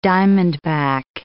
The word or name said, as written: diamondback